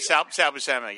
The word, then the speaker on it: Buscema: I was more of a Sal Buscema. Is that how you say it? Sal Buscema, yeah.